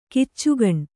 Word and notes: ಖಚಕ್ಕನೆ: khacakkane

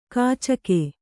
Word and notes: ಕೀಚಕ: kīcaka

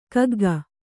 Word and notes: ಕಗ್ಗ: kagga

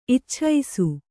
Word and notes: ಇಚ್ಛಿಸು: Kannada

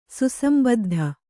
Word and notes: ಸುಸಂಬದ್ಧ: kannada

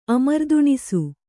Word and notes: ಅಮರ್ದುಣಿಸು: Kannada

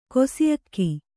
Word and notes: ಕೊಸಿಅಕ್ಕಿ: kannada

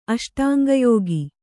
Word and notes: ಅಷ್ಟಾಂಗಯೋಗ: aṣṭāŋgayōga

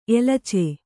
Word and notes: ಎಲಚೆ: elace

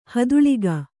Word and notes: ಹದುಳಿಗ: haduḷiga

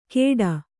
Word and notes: ಖೇಡ: khēḍa

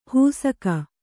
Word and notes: ಹೂಸಕ: hūsaka

